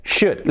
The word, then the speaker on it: should: Deberías, should. Lo primero, la pronunciación, should, should, should. Que no es should, la SH, should. Y como los anteriores en could y en would, la L fuera. Should, should.